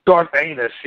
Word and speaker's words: Garth Ennis: I think it's pronounced Garth Ennis, isn't it? – Garth Ennis, yes, it is.